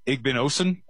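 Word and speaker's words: Igbinosun: Parker on the edge, Davidson Igbinosin, I believe is how you pronounce his last name, at corner.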